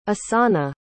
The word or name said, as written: assana